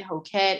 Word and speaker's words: Hokett: Y'all can follow me on Instagram at Raven Hoquette. It's just my first name and my last name. My last name is spelled H-O-K-E-T-T.